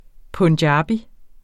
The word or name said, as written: punjabi